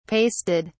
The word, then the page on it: pasted: British English